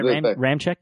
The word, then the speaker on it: Ramczyk: Like, Danielle Hunter, I think, got a sack against Ramczyk. Is that how we pronounce? – Yeah, Ramczyk, yeah.